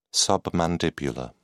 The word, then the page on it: submandibular: British English